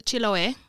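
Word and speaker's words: Chiloé: Yeah, so it's Chiloe. – Chiloe. – If the accent is where you're saying it is.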